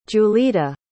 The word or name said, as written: julita